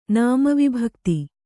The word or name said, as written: ನಾಮವಿಭಕ್ತಿ